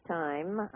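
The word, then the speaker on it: TIMME: Now, it's pronounced Time, I guess you could say, Company, Inc.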